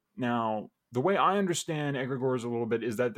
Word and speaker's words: egregore: I'm going to take a left turn here and ask you about something that I don't know a lot about, but you talk often about egregores, which I think is the proper pronunciation.